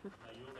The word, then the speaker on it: Mayo: M-A-Y-O. – Ah, bah ça va. – Maillot, mayonnaise.